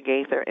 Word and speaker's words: Gaither: Well, I have a Twitter handle at Keisha Gaither, MD, and my name is spelled K-E-C-I-A. That's my first name, Keisha. Last name, G-A-I-T-H-E-R.